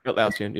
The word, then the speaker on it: Atlassian: I think that's how you say it, Atlassian, whatever. – Atlassian, yep.